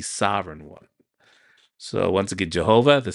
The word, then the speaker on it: Jehovah: Y-A-H-W-E-H. And then later, vowels were added to that name. So now it's pronounced Jehovah.